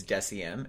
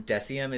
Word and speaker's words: Deciem: And it's called, the brand itself is Deciem, and they have multiple like lines within it. – Spell that for everyone. – Deciem is D-E-C-I-E-M.